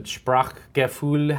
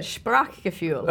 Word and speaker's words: Sprachgefühl: And, you know, like, I feel like I have a bit of, I don't know how to say this, but Sprachgefühl. How do you say oh, it? Oh, very good. Sprachgefühl. – Okay, Sprachgefühl, which you talk about in the book, which is language sense or word sense and mm-hmm.